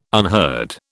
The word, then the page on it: unheard: British English